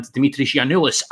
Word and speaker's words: Dimitris Yiannoulis: But tell the Hodge on Nodge listeners why you can tell us about Christos Tsiolis and Dimitris Yiannoulis, and correct my pronunciation first off. – No, your pronunciation is spot on.